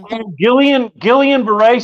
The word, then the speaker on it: Guillain-Barré: Guillain-Barré syndrome? – Guillain-Barré syndrome.